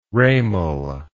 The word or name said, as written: ramal